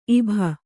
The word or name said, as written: ಇಭಿ